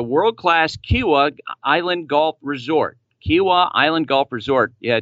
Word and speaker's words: Kiawah Island: Make sure that I pronounce it the way the locals do. How did I do right there? – That sounded good. I'm familiar with Kiawah Island, and you nailed it.